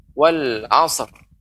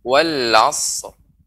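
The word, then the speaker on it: wal asr (والعصر): Maka tidak boleh langsung kita mengucapkan wal asr. Tidak boleh. Tidak boleh sod dipantulkan. Maka bagaimana cara pengucapannya? Pengucapannya yaitu wal asr.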